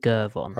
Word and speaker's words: Gervon: I'll go with Gervon. – Probably a hard G, yeah.